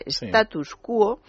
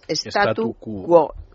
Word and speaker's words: statu quo: Es muy habitual la confusión decir status, acabado en ese, status quo, en vez de lo que se debe decir que es statu quo. – Y quo con, acento sin tilde, pero recayendo el acento en la O.